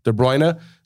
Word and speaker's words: De Bruyne: De Bruyne.